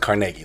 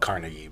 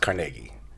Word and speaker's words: Carnegie: Well, it's pronounced Carnegie. A lot of people call it Carnegie, but it's pronounced Carnegie.